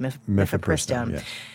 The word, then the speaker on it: Mifepristone: is it Mifepristone, thank you, Mifepristone.